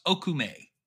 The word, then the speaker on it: Okoume: Uh, it is it is spelled O K O U M E, right? Which is pronounced Okoume.